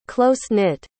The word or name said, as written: close-knit